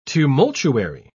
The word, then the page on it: tumultuary: British English